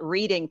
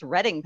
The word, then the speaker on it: Reading: So it's not Reading, Pennsylvania, it's Reading, Pennsylvania.